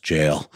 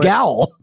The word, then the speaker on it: gaol: And then Ben very kindly patted me on the head virtually and said, I think it's pronounced jail. I learned a cool alternate spelling for jail today. It's pronounced gowl.